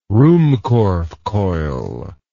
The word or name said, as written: ruhmkorff coil